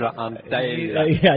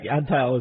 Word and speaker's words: Antheil: Georges Antheil. – I think Antheil is the way he must have said it. – Antheil. George Antheil. – George Antheil. Say it right, huh? – From Trenton, New Jersey.